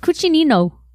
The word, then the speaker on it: Cucinino: But it's cucinino. – Cucinino. – It's spelled C-U-C-I-N-I-N-O. – C-U-C-I-N-I-N-O. – Nino. – N-I. Cucinino.